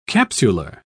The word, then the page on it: capsular: British English